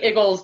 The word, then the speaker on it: Eagles: and well, I say Iggles because, like, the Northeast Philly in me that is from the part of Philadelphia that I'm from, we say Iggles, which would you would spell it as I G G L E S instead of E A G L E S.